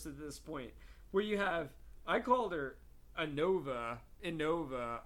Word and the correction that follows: Ivanova: I, N-N-O-V-A. Is that how you say her name? – Ivanova.